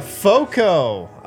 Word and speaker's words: FOCO: FOCO. FOCO. FOCO. Pronounced like Fort Collins, not spelled like Fort Collins.